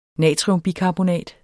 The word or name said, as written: natriumbikarbonat